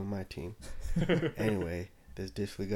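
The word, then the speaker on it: Dissly: Is that his last name? – That's how you pronounce it. Will Disley.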